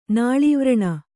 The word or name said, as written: ನಾಳೀವ್ರಣ